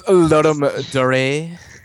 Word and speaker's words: Ludum Dare: There excuse me, it's Ludum Dare? – It's Ludum Dare.